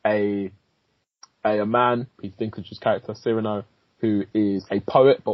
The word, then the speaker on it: Cyrano: Yeah, so it's pronounced Cyrano.